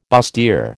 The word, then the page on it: bustier: American English